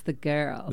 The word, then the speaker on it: Ghurls: and it's spelled G-H-U-R-L-S. – Yes, the girls.